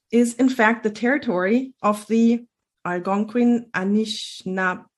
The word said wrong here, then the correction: Anishinaabeg: people. – Anishinaabeg. – I just want to say that I definitely mispronounce this word. Algonquin Anishinaabeg people, which means that these people were there way before anybody came to the country and especially Europeans, I guess.